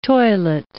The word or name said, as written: toilet